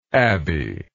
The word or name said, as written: abby